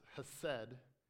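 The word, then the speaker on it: hesed (חסד): It's hesed, but you have to like put some phlegm in the first part of it.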